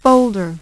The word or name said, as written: folder